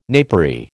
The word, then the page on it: napery: American English